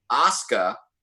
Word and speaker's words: Asuka: It's actually Asuka. That's how you pronounce her name.